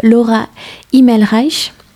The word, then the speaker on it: Laura Himmelreich: Laura Himmelreich ». C'est comme ça qu'on prononce. – Oui, presque.